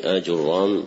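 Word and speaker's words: آجرّوم: وهذا شرح الكتاب الحادي عشر من برنامج مهمات العلم في سنته الأولى، وهو المقدمة النحوية لمحمد بن داود بن آجرام الصنهاجي. وآجرام بفتح الهمزة وضم الميم وتشديد الراء بلسان البربري، هكذا نطقه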